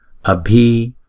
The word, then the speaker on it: abhi: Chữ bhi chúng ta đọc là bờ. Chữ bờ đứng một mình thì chúng ta đọc là bờ. Chữ bờ và chữ h ghép lại thì chúng ta đọc là bờ. Cho nên chữ này chúng ta đọc là abhi.